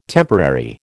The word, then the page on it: temporary: American English